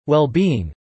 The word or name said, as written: well-being